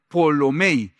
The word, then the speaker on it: Ptolomei: Ese Ptolomei sería como Polomei.